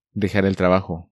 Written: Dejar el trabajo

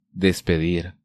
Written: Despedir

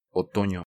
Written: Otono

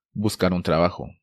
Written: Buscar un trabajo

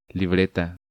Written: Libreta

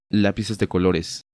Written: Lapices de colores

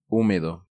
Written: Humedo

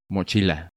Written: Mochila

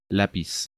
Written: Lapiz

